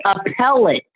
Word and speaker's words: appellate: Just like your bitch in the appellate court, she's on appeal. – Nigga, are you slow? Appellate. – Appellate, thank you.